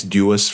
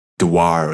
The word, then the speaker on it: Dewar: D-E-W-A-R. Dewar or Dewar. It's, I think, named after a certain Mr. Dewar? – James. – Dewar, right? – That's not how I would have expected his name to be pronounced, but yeah, okay. – Yes. My colleagues call it Dewar. I don't know. I would have said Dewar, but yeah.